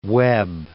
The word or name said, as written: Webb